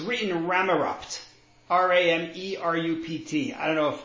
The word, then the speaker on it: Ramerupt: It's Ramot. You don't pronounce the T and the P.